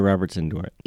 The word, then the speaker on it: Robertson-Dworet: Is that how you say her name? – Geneva Robertson-Dworet.